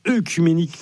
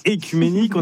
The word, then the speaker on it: œcuménique: Merci Clara, et nous sommes le 8 octobre et c'est donc l'anniversaire de l'ouverture du concile écuménique de Chalcédoine. Le 8 octobre 451, alors concile écuménique, ça peut sembler une expression un peu barbare. Déjà précisons qu'on ne dit pas œcuménique, mais écuménique.